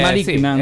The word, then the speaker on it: Malignant: Che si legge sì, così, giusto? Eh, Malignant. Sì, è di fatto sì.